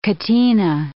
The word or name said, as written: catena